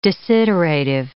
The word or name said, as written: desiderative